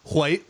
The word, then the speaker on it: white: Well, by the way, I was always told as a kid that a word like white, you're supposed to pronounce it HW. That WH sound is supposed to be white like that.